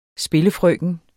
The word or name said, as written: spillefrøken